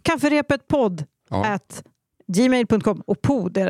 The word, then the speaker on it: pod: Alltså det, det, det uttalas ju podd, men det stavas ju pod. – Mm. Så att man inte tror att podd med två D, utan ett D.